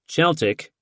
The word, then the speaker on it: Celtic: The inexperienced Celtic, whose name was pronounced Celtic, even though everyone in London insisted on pronouncing it like the Boston basketball team, promptly started copying the run-throughs verbatim, errors and all.